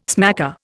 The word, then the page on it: smacker: Indian